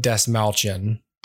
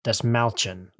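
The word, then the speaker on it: Dastmalchian: Is that how you say his name? – I think it's David Desmalchin. – Desmalchin.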